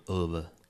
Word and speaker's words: Ove: Mine is also A Man Called Ove. Which is how you pronounce it in Swedish, trust me.